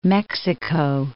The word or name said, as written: New Mexico